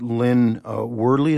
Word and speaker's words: Worley: with Lynn Worley. Is that how you pronounce her name? – That's right.